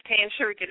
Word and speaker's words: Tan Sarika: It is Tan Sarika, just the way it's spelled.